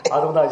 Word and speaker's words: Adonai: Adonai 是我。 – But you said it right. – Yeah, Adonai.